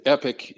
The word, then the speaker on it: EPIC: So the EPIC, and EPIC is how we pronounce the acronym EPBHC, Embedded Prevention Behavioral Health Capabilities Specialist.